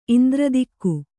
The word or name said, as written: ಇಂದ್ರದಿಕ್ಕು